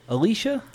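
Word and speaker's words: Elisha: Is it Alicia? – Alicia. – Oh.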